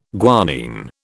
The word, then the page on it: guanine: British English